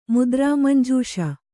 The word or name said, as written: ಮುದ್ರಾಮಂಜೂಷ